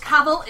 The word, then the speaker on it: Cavill: It's Cavill. It rhymes with travel. You can go look him up saying that.